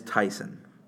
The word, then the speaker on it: Taisun: It's Taysan, for the record. The first name, you pronounce T-A-I-S-U-N as Taysan. So that part's very easy.